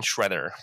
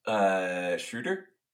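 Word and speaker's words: Schröder: So and he's named like a villain, Schroeder. – I think it's actually pronounced Schroeder. – Is it Schroeder? – With an umlaut.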